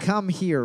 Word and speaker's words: Khmer: Khmer Rouge? I don't know how to pronounce it. K-H-M-E-R. I know how to say Rouge, though. – Khmer? – Khmer. – Khmer. – Come here, Rouge. – Come here, Rouge. – That sounds more accurate.